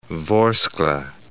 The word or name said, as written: Vorskla